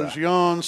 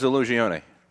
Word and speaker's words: Illusione: Say Illusion, some say Illusione. – Hey, look it up. Pronunciation is Illusione. – Yeah, yes, you are correct.